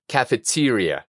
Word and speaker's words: cafeteria: Vamos a dividir esta palabra en partes. Primero tenemos café. En español continuaríamos con tería, pero en inglés la segunda E la vamos a pronunciar como I, T. Y el énfasis en esta palabra va a estar en esta sílaba, T. Y por último nos queda ría. La R, como ya sabemos, es de pirata. Ria. Teria. Cafeteria.